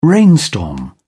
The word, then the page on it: rainstorm: British English